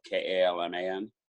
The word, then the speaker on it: Kalman: It's my last name is spelled Kalman.